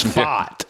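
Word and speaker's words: Pfaadt: A super long shot that pitched great, a rookie, Brandon Faadt, P-F-A-A-D-T. I believe it's Faadt is how they pronounce that.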